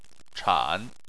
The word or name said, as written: chan3